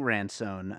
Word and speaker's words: Ransone: And James, I'm going to butcher his last name, but I think it's James Rant. Is it Ransone? Eddie's character. – Right. Yeah, I think Ransone.